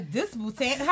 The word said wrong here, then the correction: Disputanta: How you say it? – Disputanta. – Disputanta.